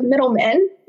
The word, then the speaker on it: Middleman: Another thing is not so much something I did, but with a name like Miranda Middleman, you're bound to have some mistakes, some tongue twisters. So my name has been misspelled, mispronounced. I often get called Middleton or Middleman with D's, but, you know, I just kind of let it roll.